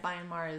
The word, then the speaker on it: Myanmar: Am I saying that right? – Myanmar? – Myanmar, yeah. Had their very first Pride.